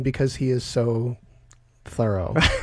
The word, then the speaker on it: Thoreau: So when Nathaniel Hawthorne first met him, Thoreau, in 1842, he wrote in his journal, "Dined with Mr. Thoreau," and he spelled it T-H-O-R-O-W. So he was spelling it phonetically.